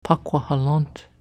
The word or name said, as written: Paquahalant